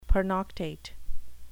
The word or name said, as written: pernoctate